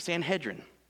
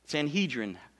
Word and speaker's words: Sanhedrin: In fact, he's so influential, he's a member of the Jewish ruling council called the Sanhedrin. Sanhedrin, whoever your pastor was when you were growing up, that's how you say it.